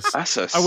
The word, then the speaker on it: Asus: It's pronounced Asus. – Asus. – Which they had a marketing campaign because it's supposed to be Pegasus.